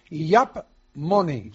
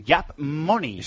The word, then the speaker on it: Yaap: Es una aplicación que se llama Yap Money. – Yap Money. – Se escribe Y-A-A-P, sí.